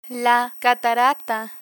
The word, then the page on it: catarata: spanish